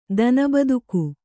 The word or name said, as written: ದನಬದುಕು